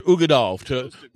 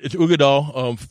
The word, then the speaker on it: Uigeadail: It is pronounced Uigeadail. – Uigeadail. – Yeah, we can grunt later. You know, we got cows and bulls out here, man. We can grunt later. You know, it's Uigeadail.